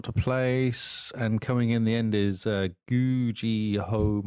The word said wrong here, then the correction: Yu-Gi-Oh: Master Duel. – Yu-Gi-Oh. – There you go.